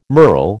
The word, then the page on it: merle: American English